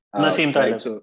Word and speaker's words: Nassim Taleb: Nassim Taleb.